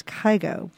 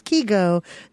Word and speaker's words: Kygo: It's a brand new, fresh hit from the Norwegian DJ Kygo, who people apparently mispronounce as Kygo. But What? – Yeah, it's Kygo. That is the proper Norse pronunciation of his name.